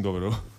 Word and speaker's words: dobro: Aquí tenemos a los del doblo. ¿Doblo? ¿Dobro? ¿Cómo es eso? ¿Cómo se dice? ¿Qué, qué es un dobro? – Dobro, dobro. Es una guitarra resonadora, una guitarra metálica.